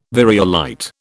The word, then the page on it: variolite: British English